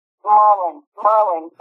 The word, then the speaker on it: Maryland: and it's Merlin, that's how you say it, that's what it's Merlin. – Oh, Merlin.